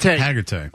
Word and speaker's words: Hargitay: Then I see the picture of, it's Mariska Hargitay. Is that how you pronounce it? – Hargitay.